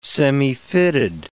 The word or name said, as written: semifitted